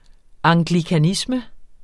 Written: anglikanisme

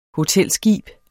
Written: hotelskib

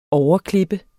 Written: OVERKLIP

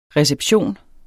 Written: RECEPTIONERNES

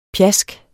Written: pjask

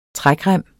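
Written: trækrem

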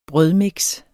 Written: brødmix

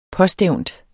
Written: påstævnt

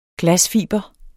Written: glasfiber

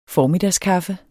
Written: formiddagskaffe

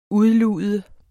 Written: udlude

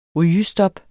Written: rygestop

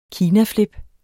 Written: KINAFLIPPER

